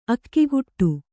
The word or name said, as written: ಅಗ್ಗಡ